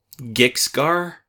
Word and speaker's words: Gixgar: Gixgar? – Yes. – I guess is the pronunciation. – We think, yeah. – G-I-X-G-A-R. That's the one I saw.